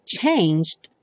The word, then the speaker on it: changed: Changed é uma palavrinha um pouco difícil de pronunciar, porque como o E não aparece, você não vai dizer changed. Tem que tomar cuidado, então, para conseguir passar do G para o D.